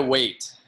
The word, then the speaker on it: Waite: Yeah, Jeremiah Waite. Jeremiah, just like you're waiting in line.